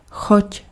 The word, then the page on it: choť: /xɔc/